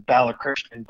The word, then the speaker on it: Balakrishnan: The full last name is Balakrishnan, but I've gotten over the years to where it's hard for people to pronounce it.